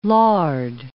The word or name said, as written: lard